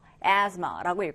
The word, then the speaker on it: asthma: 얘는 뭐냐면 asthma라고 읽고요. 천식이라는 의미, 호흡기 질환 중에 하나죠. 천식이라는 의미의 단어가 되겠습니다. 발음은 asthma. 얘가 th나 d 사운드가 아니라 z 사운드가 나요.